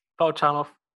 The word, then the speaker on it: Valchanov: Valchanov.